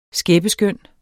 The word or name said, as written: skæppeskøn